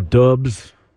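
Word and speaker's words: Doubs: Romeo Dubs out with an ankle. – Oh, yeah? – Ruled out with an ankle. – Is that how you say it? – Yeah.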